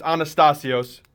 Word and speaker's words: Anastasios: Pretty close. It's Anastasios, but it's as Greek as it gets.